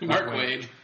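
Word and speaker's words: Mark Waid: Mark Watts. – Wade? – That's how you say it? – Mark Wade.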